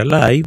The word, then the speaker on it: live: Se escribe live, L-I-V-E, se pronuncia live.